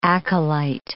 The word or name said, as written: acolyte